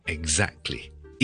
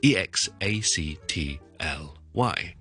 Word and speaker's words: exactly: 最常听到咧系 exactly, E X A C T L Y。呢个我哋以前都讲过啦，exactly, exactly, exactly。